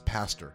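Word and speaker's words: Pazder: It was a 1980 book co-written by Canadian psychiatrist Lawrence Padzer. It's pronounced Pastor. I'm sorry.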